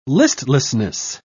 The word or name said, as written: listlessness